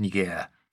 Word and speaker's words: Niger: Also es geht um Niger. So heißt das bei uns, aber französische Aussprache wird mehr auf der hinteren Silbe betont Niger.